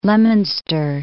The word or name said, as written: Leominster